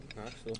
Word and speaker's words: Knoxville: Yeah, Knoxville. That's where my cousins live. Well, you said it incorrectly. Knoxville. Knoxville. You, you gotta slur it all together.